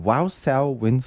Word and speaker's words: Wausau: In Wausau, how do you pronounce the name of the town? Wausau, Wisconsin.